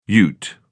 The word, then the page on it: ute: British English